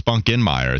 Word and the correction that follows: Spunkmeyer: It's actually Otis Spunkmeyer. You're right. Spunk. So I put the N in it, and I apologize. Yeah, for some reason I saw that extra. I made it another syllable. I didn't mean to. It's Taysom Hill all over again. It's Taysom Hill, and it's Otis Spunkmeyer, not Otis Spunkinmeyer.